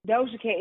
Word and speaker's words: Doja Cat: Doja Cat. Is she cute? What's she look like? – Doha. I thought it was Doha. How you say it? – Doja Cat. Like, okay. – Oh, Doja Cat.